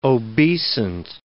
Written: obeisant

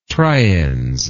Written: triens

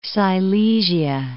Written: Silesia

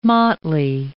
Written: motley

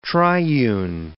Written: triune

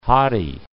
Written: hottie

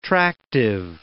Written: tractive

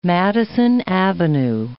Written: Madison Avenue

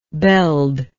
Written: beld

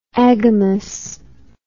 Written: agamous

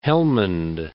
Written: Helmund

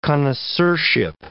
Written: connoisseurship

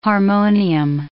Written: harmonium

